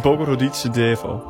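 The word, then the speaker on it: Bogoroditse Devo (Богородице Дево): Het, ja, maar hoe moet ik dit uitspreken? Het Bogori... – Ja, ze hebben het net gezongen. – Ja, weet ik, maar ik vind het altijd moeilijk. – Bogoroditse Devo. – Precies, dat.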